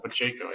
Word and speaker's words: Pacheco: Pacheco, I think that's how he... Yes, that's how you say his name.